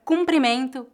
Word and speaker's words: cumprimento: A palavra cumprimento, com U, é usada para saudar ou parabenizar alguém. Pode ser usada como substantivo ou verbo. A pronúncia dessa palavra é feita com U nasal.